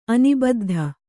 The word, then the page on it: ಅನಿಬದ್ಧ: Kannada